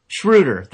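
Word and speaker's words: Schröder: Schroeder.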